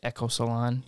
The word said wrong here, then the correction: Eco Salon: Yeah. – Eco Salon.